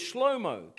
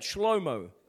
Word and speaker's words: Shlomo: And so his son, the pronunciation for Solomon in the Hebrew is Shlomo. Can you say that? Shlomo. Doesn't quite sound the same to us, does it? Shlomo, that's Solomon.